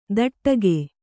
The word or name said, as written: ದಟ್ಟಗೆ